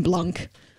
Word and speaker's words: Blanc: As someone who speaks French, it made my skin crawl every time someone pronounces the C in Blanc.